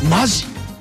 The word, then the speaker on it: 本気: 今日のエンディングナンバーはタレントの本田梨沙さんの誕生日ということで、 1989 年のサードシングル本田梨沙で本気と書いてマジを聞きながら分かるです。